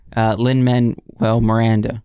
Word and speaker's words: Lin-Manuel Miranda: But I mean, we've had a few coming from Broadway to the big screen with, I'm going to butcher his name, Lin-Manuel Miranda.